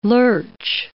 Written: lurch

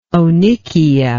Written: onychia

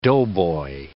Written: doughboy

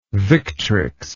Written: victrix

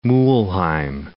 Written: Mulheim

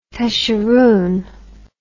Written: tusheroon

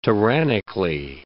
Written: tyrannically

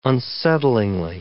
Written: unsettlingly

